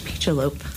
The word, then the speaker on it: Pichelope: It's actually pronounced Pichelope, like a peach with antlers.